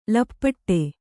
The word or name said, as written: ಲಪಾಟಿ